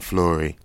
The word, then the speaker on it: Fleury: Another one, another one who sunned me and, and, and told me how to pronounce his name. His name is not yes. – Will Fleury. It's Will fucking Fleury.